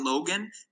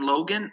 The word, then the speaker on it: Logan: So my name is Devontae Logan, and that's spelled D-A-V-O-N-T-E. Last name Logan, L-O-G-A-N, and then Megan as well on LinkedIn.